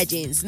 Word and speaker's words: JNS: Después regresaron como JNS, así, pero pues igual se pronuncia Jeans, ¿no?